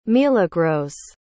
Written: milagros

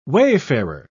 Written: wayfarer

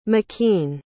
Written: makeen

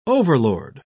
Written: overlord

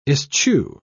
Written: eschew

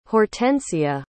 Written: hortensia

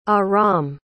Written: Aram

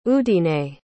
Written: udine

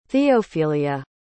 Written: theophilia

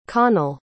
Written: conal

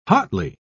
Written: hotly

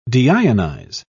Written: deionize

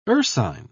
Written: ursine

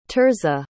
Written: Tirzah

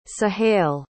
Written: suhayl

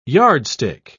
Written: yardstick

